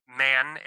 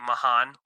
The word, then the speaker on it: Mahan: And definitely going to get a good look at the left guard battle with Brendan Mann, a.k.a. Mahan, which is how it should be pronounced, and Greg Van Roten.